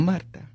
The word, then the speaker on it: Marta: It's Marta. Mm. – mm. It says, No, Marta.